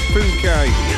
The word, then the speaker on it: Phuket: I think that's how you say it. Let's not read it out the way it's spelt. We've not long started, have we? I think it's Phuket. We'll leave it at that.